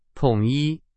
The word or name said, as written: 统一